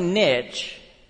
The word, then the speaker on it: niche: We call it a niche. All right. But if I say niche, I'm going to get 20 emails this week saying it's pronounced niche. So I'm going to say niche this morning.